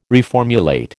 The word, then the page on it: reformulate: American English